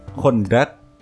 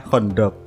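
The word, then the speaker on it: Khandaq: Dulu yang per perang Hondak, tapi kamu bacanya Hondok. Aku waktu ngaji dulu diajarin sama guru ngajiku itu perang Hondak nggak Hondok yang bener itu yang gitu jadi bisa diperbaiki lagi ya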